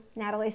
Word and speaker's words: Nataly: Nataly spelled with a Y.